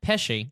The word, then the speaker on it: Pesci: Pesci.